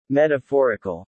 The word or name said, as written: metaphorical